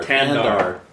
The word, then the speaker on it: Thandar: Tandar. H is silent.